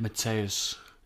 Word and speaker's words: Mateusz: It is Mateusz, isn't it? – Mateusz. – Is that how you pronounce it? – I think it's Mateusz, yeah.